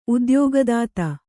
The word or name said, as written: ಉದ್ಯೋಗದಾತ